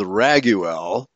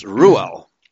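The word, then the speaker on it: Reuel: People assume that Ruel, here it's pronounced or spelled Raguel, but it's usually spelled and pronounced Ruel, okay, which means friend of God.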